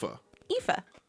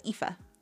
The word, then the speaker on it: Aoife: Aoife. Kind of like Eva, but Aoife. – Yeah, and they say in some dialects they would pronounce it more like, it would be more like Eve or Eva.